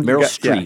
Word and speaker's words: Meryl Streep: Meryl Streep.